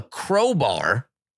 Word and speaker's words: crowbar: Because I was saying chrome bar in a previous episode and Phil got on me. So I wanted to make sure. Crowbar.